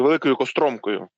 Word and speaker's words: Великою Костромкою: На лівому березі її більше, а на правому березі Дніпра в Херсонській області є от частина зверху між Осокорівкою, Нововоронцовкою, Великою Костромкою і вниз аж до Херсона. Я правильно змальовую географію? – Так, тільки Великою Костромкою. – Костромкою. Я дякую вам за ці наголоси.